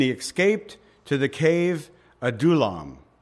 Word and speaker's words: Adullam: That's how you say it. I've heard the Hebrew words butchered so much that I'm really trying to come up with the correct pronunciation, so I'm hoping that I've got it right. I've looked at a number of references, and tonight we have some pretty different names here, but that's Adullam.